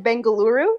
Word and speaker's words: Bengaluru: Sometimes people call it Bengaluru, so either is acceptable.